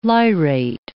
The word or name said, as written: lyrate